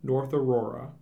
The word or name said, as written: North Aurora